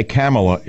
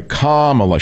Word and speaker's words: Kamala: Because if I say Kamala, it's a slur, right? – Right. – It's Kamala? Kamala, sorry. Kamala. She's going to calm you down. It's like a Jewish phrase. Kamala, darling.